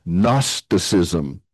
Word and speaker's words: Gnosticism: And that is known as Gnosticism. It starts with a G, but the G is silent. It comes from the Greek word gnosis, and we don't pronounce the G, they do.